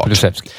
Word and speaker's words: Kulusevski: Kulusevski från start. – Kulusevski. Ja, just det. Så heter han.